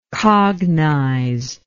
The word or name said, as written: cognizer